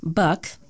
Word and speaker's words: Buyck: As previously reported, staff union president Mark Buck, that's spelled B-U-Y-C-K,